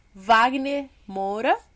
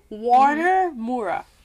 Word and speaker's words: Wagner Moura: How do you go from Wagner Moura to Warner Moura? – Warner!